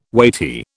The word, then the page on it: weighty: British English